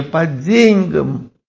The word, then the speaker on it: по деньгам: Обратите внимание на ударение по деньгам, удар по деньгам, на первый слог приходится этот удар, ударение.